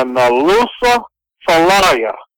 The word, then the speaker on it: Nalusa Falaya: But they called it, it's a two-word name, the Nalusa Falaya. It's spelled N-A-L-U-S-A, Nalusa, which usually means man, and Falaya, F-A-L-A-Y-A.